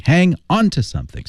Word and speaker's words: hang on to something: Hang on to something. Stress、 oh. the preposition. – 好, 它的重点重读在于那个介词 on to something 上面。